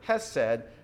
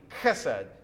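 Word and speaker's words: chesed (חסד): The word loving kindness comes from the Hebrew word hesed. It's actually chesed. You have to kind of, you know, use some guttural German there, or Hebrew, to get that actual pronunciation out.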